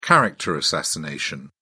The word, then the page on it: character assassination: British English